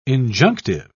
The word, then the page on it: injunctive: British English